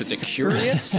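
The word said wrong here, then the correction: cuirass: Is that how you would pronounce it? Curious? – Curass. – A curass. He's got a big curass on, which is strategically placed.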